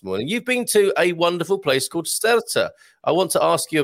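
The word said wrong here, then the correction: Sertã: I think it's should be pronounced Sertar. – Sertar. – Because the accent is over the A. So yeah. The rule generally being that the accentuation is on the accent. So I think it's Sertar.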